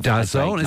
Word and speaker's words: DAZN: It was DAZN. Is that how you pronounce it? Yeah, DAZN. – Well, that's how I was told to pronounce this.